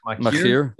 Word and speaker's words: Machir: How do you say that? – Machir. – It's Machir. Yeah. Machir. Uh-huh.